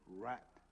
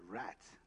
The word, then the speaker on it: wrath: We shall be saved from wrath. How do you say that? Wrath. Wrath. I thought I said rat. No, not rat.